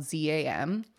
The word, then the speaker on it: Zam: But So Bangcha was like their legacy group, but the first group that DSP debuted was called Dem and it's spelled Z A M. – Zam. – Zam. it was supposed to be like jam, but like with a oh, Z.